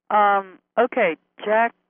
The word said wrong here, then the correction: Jacques: Fresco. Is that how you pronounce his first name? – Yeah, Jacques Fresco. Yeah. – Jacques Fresco, sorry.